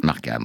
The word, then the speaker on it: Markham: Beryl Markham. – Markham, voilà. Je voulais bien la prononcer pour faire semblant de la connaître, c'est raté.